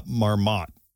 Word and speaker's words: Marmotte: Their famous groundhog is Fred La Marmotte. – Fred La Marmotte. Is that how you say it? – Probably. – Yeah. – Marmotte. – Like, the T would probably be silent.